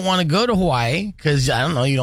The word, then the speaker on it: Hawaii: Hawaii. – Hawaii. That's how you say it.